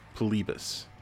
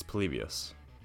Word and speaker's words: Polybius: It's about a video game named Polybius, and it was rumored to be in the Portland area in the early '80s, and it was rumored to be an experiment. – You mispronounced it. It's Polybius.